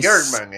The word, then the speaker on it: Kirkman: Robert Kirkman, para mí... Kirkman, exacto.